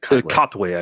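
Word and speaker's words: Katwe: I went with the Queen of Katway. Or I may be mispronouncing it. Is it Katway, I believe. – Katway, thank you, yes.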